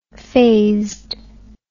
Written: phazed

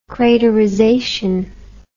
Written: craterization

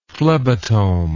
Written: phlebotome

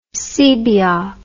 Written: sebeok

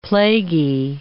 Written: plaguey

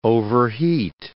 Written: overheat

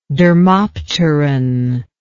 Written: dermopteran

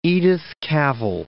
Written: Edith Cavell, Mount

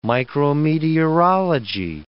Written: micrometeorology

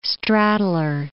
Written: straddler

